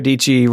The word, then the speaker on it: Dietschy: Yeah, Sarah Dietschy rhymes with peachy is her like little phrase.